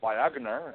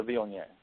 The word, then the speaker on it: Viognier: I have people ask for Viagner, and I said no, that's the Viognier.